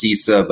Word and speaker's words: CISA: It's pronounced CISA, but I want to make sure that the audience can find it.